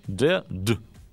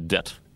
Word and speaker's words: dead: Последняя буква слова она произносится так же, как она должна произноситься на любой другой позиции. Так что будьте внимательны. Да, и какое-нибудь английское слово dead, да, то есть мертвое, оно вот звучит именно так. Dead, а не dead.